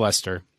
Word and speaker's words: Leicester: And that is West Ham, Villa, and Leicester. So let's look at these three, especially from a spend standpoint. – Yeah, so we have Leicester. We'll start with Leicester.